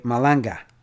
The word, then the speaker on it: Malanga: Descarga Malagna. Wow, I can't even pronounce it. I'm tongue-tied here. Anyway, Malanga. There you go.